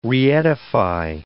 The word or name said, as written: reedify